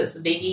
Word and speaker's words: tzatziki: How do you pronounce it? Tzatziki? – Tzatziki. – Yeah. You don't pronounce the T.